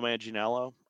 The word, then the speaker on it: Manganiello: But when we get to the third one, Joe Manganiello, I think is how you say his name. – Yes.